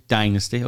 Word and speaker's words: Dynasty: Americans say Dynasty film. – Do they? – Mm-hmm. – Okay. Well, that's their problem. You know, by the way, it's also being reshot in Serbia. We'll come on to that in a moment. John James, who from the Dynasty or Dynasty? – Dynasty. – Okay, you Americans. Okay, it's Thanksgiving. I'll succumb to the American pronunciation.